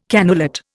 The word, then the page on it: cannulate: Indian